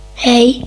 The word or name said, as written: hei